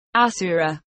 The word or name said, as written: asura